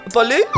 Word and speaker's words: Bolu: So it's actually Bolu. – Bolu? Okay. – You do the Lu is up. Bolu.